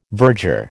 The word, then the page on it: virger: American English